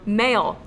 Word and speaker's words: Male: And how do you say male? – Male, yes. – Okay, so you say it male? – It's just male.